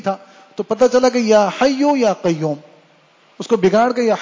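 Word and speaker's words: یا حی یا قیوم: تو اس کو سمجھایا بتایا کہ بھائی یا حجو کجو نہیں ہوتا, یہ ہوتا یا حیو یا قیوم.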